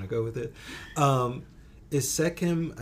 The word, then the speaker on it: Sekhem: Is Sekhem, am I pronouncing it correctly? – Sekhem, yes.